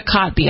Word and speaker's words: Kotb: Hoda, and I always say wrong, Hoda Kotb, I believe is how you say her name, but I always want to call her Kotb is how she spelled it. Yeah. – Right. I always want to call her Cobb because I've heard that before.